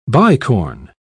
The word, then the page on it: bicorn: British English